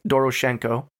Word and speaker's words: Doroshenko: The first body that was found was Yuri Doroshenko.